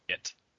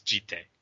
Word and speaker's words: Jitte: Yeah, I've said this before on the podcast, but it's not pronounced Jit or Jitty. It's Jitte. And there's no, like, question about it either. It's not like, it could be pronounced this way. It's a fucking Japanese word, and that's how it's pronounced.